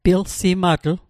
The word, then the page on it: pilsimatl: Mi'gmaq